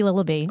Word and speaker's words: Lilleby: So it's Julie Lilleby.